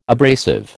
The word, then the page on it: abrasive: American English